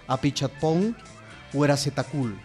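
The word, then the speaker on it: Apichatpong Weerasethakul: que se llama Apichatpong Zetacul. – Lo dijiste espléndido, realmente creo que nadie lo podría pronunciar de esa manera.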